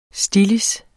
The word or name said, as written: stillids